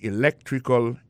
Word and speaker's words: electrical: I majored in electrical engineering. Electrical engineering. Ça veut dire accent tombé dans electrical, là, c'est sur deuxième syllabe là. Electrical engineering. C'est sur deuxième syllabe là. Elect là, plus appuyé.